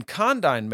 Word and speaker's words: condign: The third one is called condign merit, and it's spelled C-O-N-D-I-G-N. Okay, condign merit.